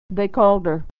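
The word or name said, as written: they called her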